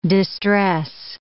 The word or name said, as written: distress